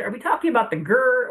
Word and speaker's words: GERD: But when you put the D at the end and you pronounce it like GERD, that's gastroesophageal reflux disease.